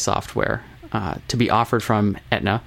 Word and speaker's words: Aetna: A-E-T-N-A, you know them? – Pronounced Aetna.